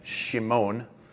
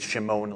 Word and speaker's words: Shimon: And the Greek pronunciation, Petros, and then Kephas, and then Shimon, which is easy to remember if you remember how Michael Jackson would say that phrase, Shimon, a lot back in the 80s.